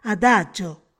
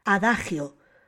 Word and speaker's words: adagio: Al tratarse de un extranjerismo, conserva su pronunciación original, en italiano, o sea, adagio, y debe escribirse en los textos con resalte tipográfico en cursiva. Se desaconseja la pronunciación adagio, porque esa se corresponde con la otra acepción del vocablo, es decir, con una frase breve de carácter moral.